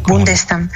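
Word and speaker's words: Bondestam: Y Linda von der Stam, esa O se pronuncia como una U, Bundestam.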